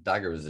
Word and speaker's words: dagga: So there's for sure a culture that just, I mean, there's daggers is known, that's poor pronunciation, d-a-g-g-a,